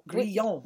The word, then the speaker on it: Grillon: I do pronounce it like that a lot, but I prefer, people can't spell that. So I say, turn the grill on. – Okay. – It's just easier. – So when you say it, you say, grill on? – I say grill on, yes.